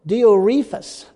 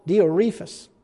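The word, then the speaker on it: Diotrephes: And it says, I wrote to the church, but this guy pronouncing his name is Diorephus, is actually how you pronounce it, Diorephus.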